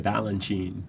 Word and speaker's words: Balanchine: And it sounds like ballet, Balanchine.